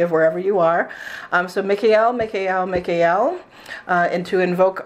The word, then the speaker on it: Mikael: The pronunciation that I work with is Mikael, because El is an ancient Hebrew name for God.